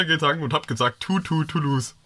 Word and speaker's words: Toulouse: Und die Manufakturstelle ist in Toulouse, wo ich übrigens auf Austausch war. – Finde ich auch lustig, dass eine französische Stadt einfach so ausgesprochen wird, als wenn man im Englischen verlieren sagen würde.